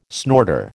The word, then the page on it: snorter: American English